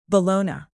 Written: bologna

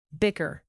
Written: bicker

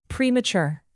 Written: premature

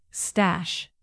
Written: stash

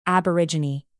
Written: aborigine

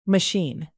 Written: machine

